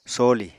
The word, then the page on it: some: Apatani